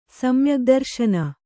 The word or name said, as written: ಸಮ್ಯಗ್ದರ್ಶನ